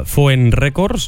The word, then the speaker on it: Foen Records: De fet, has fitxat no fa massa per Foen Records, no sé si ho pronuncio bé. – Sí, correcte. Sí.